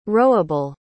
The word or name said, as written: rowable